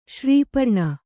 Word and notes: ಶ್ರೀಪರ್ಣ: srī parṇa

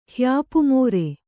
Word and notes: ಹ್ಯಾಪುಮೋರೆ: Kannada

